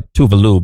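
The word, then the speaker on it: Tove Lo: Then you got Tovlo. Technically, she goes by Tuvalu, but no one pronounces it right.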